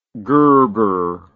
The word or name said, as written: gerber